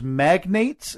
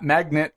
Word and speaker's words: magnates: I think the proper term, Steve, is magnates. Is that how it's pronounced? – Yeah. – Magnet? Magnate? – Yeah, not magnet. No, not magnet.